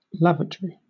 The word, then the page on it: lavatory: British English